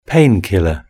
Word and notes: painkiller: British English

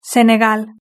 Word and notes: senegal: Spain Spanish

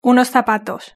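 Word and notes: unos zapatos: European Spanish

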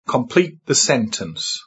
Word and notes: complete: British English

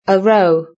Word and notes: a row: British English

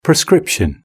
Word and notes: prescription: British English

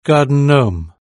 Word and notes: garden gnome: British English

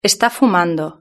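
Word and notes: esta fumando: Spain

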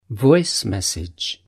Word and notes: voice message: British English

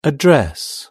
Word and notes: a dress: British English

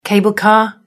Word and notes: cable car: British English

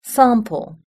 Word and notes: sample: British English

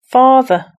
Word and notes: father: British English

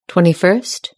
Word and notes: twenty first: British English

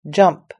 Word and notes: jump: British English

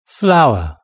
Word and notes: flower: British English

